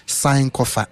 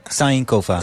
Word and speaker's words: Sankofa: Zo spreek je het uit. – Ja, zo zeg je het eigenlijk. Sankofa. – Sankofa.